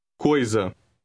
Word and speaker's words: coisa: Coisa. Coisa. – Note that the S in this word has a vowel before and after it. It is pronounced Z, as in the English word zeal.